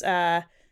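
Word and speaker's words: Mehran: The first one is Mehron. Did I say it right? – Yeah.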